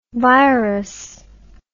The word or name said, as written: virous